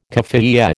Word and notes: kaffiyeh: American English

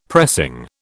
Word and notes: pressing: British English

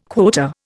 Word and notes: quota: Indian

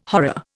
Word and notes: horror: Indian

